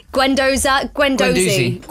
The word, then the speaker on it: Guendouzi: Guendouzi.